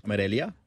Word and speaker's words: Amarilla: Celeste Amarilla, A-M-A-R-I-L-L-A. I'm terrible. – Amarilla? – Amarilla.